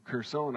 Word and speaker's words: Kherson: And he's recently returned with other soldiers to Kherson, I believe is the right way to pronounce it.